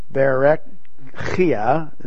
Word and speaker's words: Berechiah: the son of Berechiah, is the way it's pronounced in Hebrew, the son of Iddo, the prophet.